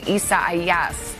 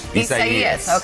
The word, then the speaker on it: Isaias: We'll start with the latest on what is now officially Hurricane Isaias. Did I say that right? – I think so. – Isaias.